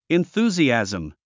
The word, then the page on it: enthusiasm: inθúːziæ`zm; インスージアズム